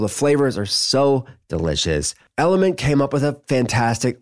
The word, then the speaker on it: LMNT: It's spelled L-M-N-T, Element.